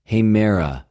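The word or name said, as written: ἡμερα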